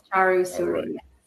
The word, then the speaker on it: Charu Suri: Charu Suri. – Yes, Charu the accent on the first syllable. Okay. Charu Suri.